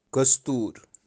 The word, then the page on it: Kostoor: Kashmiri